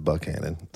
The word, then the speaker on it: Buchanan: It's spelled Buchanan, but everyone calls it Buchanan.